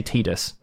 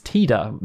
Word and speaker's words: Tidus: And he said, oh, I'd say Tidus. And they were like, okay, that'll do. You know, like, but I think they showed him the Japanese version, like, it must have shown him the Japanese version, and he was been like, because in Japanese it's Tida, makes sense, right?